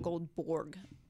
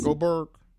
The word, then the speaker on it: Goldberg: It's not Goldborg. – Goldberg? – Yes. – Goldberg.